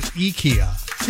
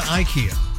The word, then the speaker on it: IKEA: But they say that some of the YouTube tutorials for putting the furniture together, if you watch them, the Swedish folks are pronouncing it IKEA. – Really? – And not IKEA.